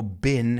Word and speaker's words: been: Suena más como been. Have you ever been there? – Have you ever been there? – Right? Has estado. – Been. – Pero cuidado, porque también hay gente que pronuncia eso como la otra palabra. Have you ever been there? – Yeah. – Es más británico, pero yo lo pronuncio como been.